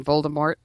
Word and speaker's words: Voldemort: Rowling came out and said afterwards, you know, you guys aren't supposed to pronounce the T on Voldemort.